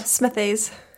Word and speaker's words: Smithies: Yeah, Smithies.